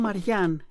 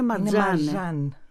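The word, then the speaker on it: Marjane: Εγώ την έλεγα τόσα χρόνια Μαριάν. Είναι Μαρζάν. Είναι Μαρζάν.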